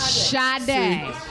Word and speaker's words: Sade: Can we in 2017 take the R out of her name, black people? Right? It's Sade. We've been mispronouncing this.